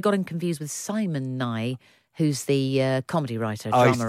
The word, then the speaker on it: Nighy: I don't think he pronounces it Nighy. I think it's Nye.